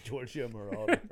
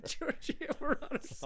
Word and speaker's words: Giorgio Moroder: Well, but he Giorgio Moroder. – Giorgio Moroder.